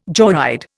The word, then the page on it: joyride: Indian